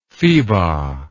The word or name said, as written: pheba